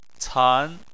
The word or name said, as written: can2